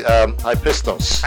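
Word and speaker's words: hypistos (ὕψιστος): This is from the Greek hypothesis. Did I say that correctly? I want to make sure. – Let's see here. The Greek hypothesis. – Yes. No. Actually, hypistos.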